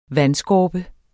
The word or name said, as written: vandskorpe